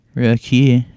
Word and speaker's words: Roku: Roku. That's how you pronounce it, 'cause it's from Japan.